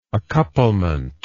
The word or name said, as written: accouplement